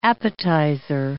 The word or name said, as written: appetizer